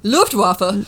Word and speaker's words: Luftwaffe: Luftwaffe.